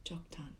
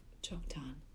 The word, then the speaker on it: Joktan: All these were the sons of Joktan, or Joktan, depending on where you're from, how it's pronounced.